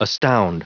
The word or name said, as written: astound